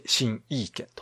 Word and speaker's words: 形意拳: 形に意味のある拳と書いて、新意見と。 – はい、ふうに読むんですが、これは日本語タイトルなんですね。